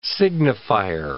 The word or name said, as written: signifier